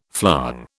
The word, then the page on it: flong: British English